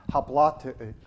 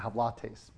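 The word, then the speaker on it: haplotēs: It comes from that Greek word pronounced hoplates. Okay, hoplates. It means simplicity, but also means singleness.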